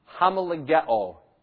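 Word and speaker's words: Homologeo (ὁμολογέω): Homologeo is how it would be pronounced in Greek.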